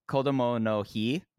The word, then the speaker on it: Kodomo no Hi: It is Kodomo, let me repronounce again, Kodomo no Hi, which is a public holiday in Japan, which takes place annually on May 5th and is the final celebration in Golden Week.